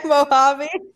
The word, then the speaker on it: Mojave: Mojave. Okay, Mojave.